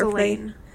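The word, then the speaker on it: Ghilane: The clue that they do find in this detour says that they need to drive 1 mile north to Kasar Galana. I don't know if we're pronouncing that right. – Galane. It's an E at the end.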